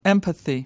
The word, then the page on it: empathy: American English